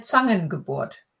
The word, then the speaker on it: Zangengeburt: Es war eine Zangengeburt. Zangen. Oh, can you say it properly? – Es war eine Zangengeburt.